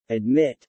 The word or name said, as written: admit